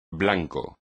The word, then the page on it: blanco: espagnol